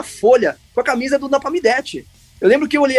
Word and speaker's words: Napalm Death: Tinha um deles que apareceu várias vezes com a camiseta do Nepal Death Agora eu falei errado, é Napalm Death.